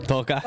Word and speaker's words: toca: How do you say touch? – I mean, toca.